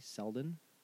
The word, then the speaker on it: Selden: His name, and I don't know if I'm pronouncing this right, but it's Rodji, R-O-D-J-I, Rodji Selden. It's like Sheldon without an H.